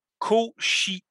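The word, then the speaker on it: Košice: Fait qu'il dit, ça se prononce « Kosice ». – Bon, ils viennent de Kosice en Slovaquie.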